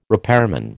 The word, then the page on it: repairman: American English